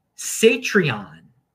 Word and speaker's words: Satreon: And here we got something called Satreon, which rhymes with Patreon, is a non-custodial Lightning version of Patreon and similar to y'all's and other paywalls.